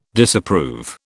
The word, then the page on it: Disapprove: British English